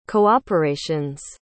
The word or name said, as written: cooperations